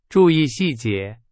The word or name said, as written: 细节